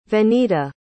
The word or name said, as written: venita